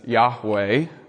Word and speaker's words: Yahweh: which is sometimes pronounced by Christians as Yahweh, but which is traditionally never uttered by Jews.